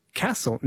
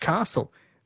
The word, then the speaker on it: Castle: Jan says there is definitely no R in Castle. Never has been. In addition, the movie is called The Castle and pronounced Castle, not Castle.